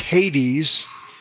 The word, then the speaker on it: Hades: Hades, pronounced in English Hades, that's the general word for hell, and it corresponds to the Hebrew word Sheol.